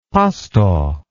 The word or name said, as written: pasto